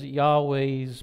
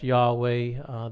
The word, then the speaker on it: Yahweh: You have Lord in all capitals there, L-O-R-D, all capitals. When you see that in the scriptures, that is Yahweh's personal name. At least we believe it's pronounced Yahweh. There is no vowels in the Hebrew language. So we believe it is pronounced Yahweh.